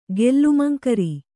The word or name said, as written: ಗೆಲ್ಲುಮಂಕರಿ